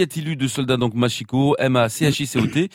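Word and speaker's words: Machicot: Alors, Machico, M-A-C-I-C-O-T, Machico, M-A-C-I-Q-U-O-T, oui. – Et on trouve même Machicote, M-A-C-H-I-C-O-T-E, qui correspond à la prononciation espagnole et à celle ancienne à l'Ariège, justement.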